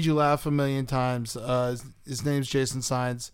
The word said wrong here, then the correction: Saenz: His GoFundMe is Sines Spines. How do you spell that? – Well, it's his name is Sainz.